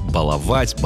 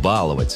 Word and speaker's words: баловать: Единственно верным считался вариант «баловать, балуемся, балованы». Вариант «баловать» с ударением на «а» помечался как разговорный. Но в 2025 году вышло сразу два словаря, которые признают равноправными оба варианта. Это большой словарь ударения русского языка и орфоэпический словарь русского языка как государственного. Так что сегодня можно говорить «баловать», а можно «баловать». Ошибки не будет.